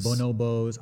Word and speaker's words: bonobos: Bonobos.